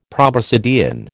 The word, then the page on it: proboscidean: American English